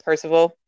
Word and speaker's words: Percival: So, Ty Sheridan plays Parvazal. Parvazal, I can't say his name. – Percival? – Z. Yeah, Percival.